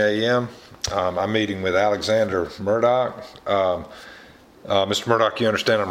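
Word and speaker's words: Murdaugh: He says Murdoch. – Yep. And I've been told that Murdoch was the more formal way to pronounce his name, but Murdoch was how they pronounced it amongst friends.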